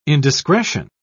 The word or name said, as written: indiscretion